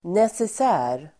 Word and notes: necessär: neses'ä:r

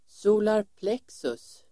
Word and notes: solarplexus: [²s'o:larplek:sus]

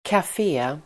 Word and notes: kafé: [kaf'e:]

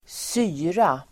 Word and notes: syra: [²s'y:ra]

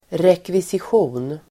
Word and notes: rekvisition: [rekvisisj'o:n]

